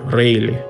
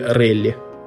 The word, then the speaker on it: Рейли: Ну вот, например, есть такой человек Уолтер Рейли или Релли – по-разному э, его фамилию произносят.